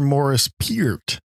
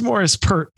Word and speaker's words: Morris Pert: Is that Morris Pert or Morris Peart? – Right. Yeah, exactly. This is Morris Pert, P-E-R-T. Yeah, good question. – I guess in the UK, you just say it the way it's spelled, yeah? – That's right.